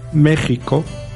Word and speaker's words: México: nosotros también podemos escribir estas palabras con X se pronuncia, sin embargo, J, como la he pronunciado yo ahora. Nunca está permitida la pronunciación México o Axaca o Texas. Los mexicanos tampoco la hacen, aunque figure la letra X.